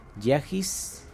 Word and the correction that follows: Yajaira: Ah, Yajaira.